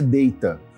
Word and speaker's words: Data: ele corrige que a pronúncia é Data.